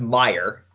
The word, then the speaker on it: Mayer: You think potentially Iger would have preferred Mayer to take over? Yeah. So first of all, it's I believe it's pronounced Meyer, even though it's spelled Mayer.